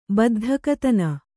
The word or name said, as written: ಬದ್ಧಕತನ